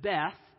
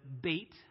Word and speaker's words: Beth: Now, Aleph over verse 1, over verse 9, it says Beth. Technically, we should pronounce it Beit.